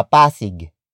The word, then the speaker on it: Pasig: For example, sa Pasig.